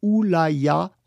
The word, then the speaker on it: L'Oréal: L'Oréal, prononcé Oulaya en chinois, signifie la beauté qui vient d'Europe.